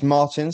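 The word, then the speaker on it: Martyn: It's Martin, spelled with a Y, because my parents like me to be different.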